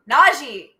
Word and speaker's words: Najee: He has said several times it's Najee, exactly yeah.